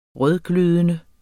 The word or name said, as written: rødglødende